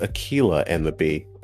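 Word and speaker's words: Akeelah and the Bee: It's actually pronounced Akilah and the Bee.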